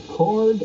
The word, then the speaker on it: Cord: Well, you've got your uh, cord or chord.